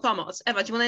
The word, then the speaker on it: POMOC: And it's spelled with a C at the end, so it can be a bit confusing because, you know, if we were to stick to English pronunciation, it should be POMOC. But the reason why we say POMOC is it's also a word in Polish, so spelled with a C, it means help.